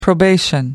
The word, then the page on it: probation: American English